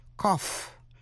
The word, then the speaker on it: cough: Cough, nghĩa là ho, G-H phát âm là f. Cough drops là kẹo ho. O-U-G-H phát âm là ow trong chữ bow, B-O-U-G-H, bow là cành cây.